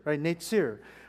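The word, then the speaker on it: Netzer (נצר): And the Hebrew word for branch is pronounced Netzer, which sounds like Nazarene.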